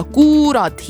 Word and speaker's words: kurat: Ja alguses hääldati seda kuurat hiljem juba siis lühemalt.